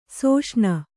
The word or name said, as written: ಸುಷೇಣಿ